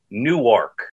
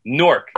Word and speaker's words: Newark: You know, I grew up in an area, let's see if I can pronounce it right for you, Newark. – That's the gross airport. – Yeah, Newark.